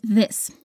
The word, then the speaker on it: this: Be careful with the pronunciation of this with the th.